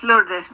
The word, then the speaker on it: Lourdes: Yes, Lourdes.